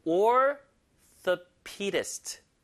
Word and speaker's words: orthopedist: Orthopedist. 자, 제 발음 잘 들어보세요. Orthopedist. 힘을 얻어주세요. Pedist.